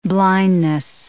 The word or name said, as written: blindness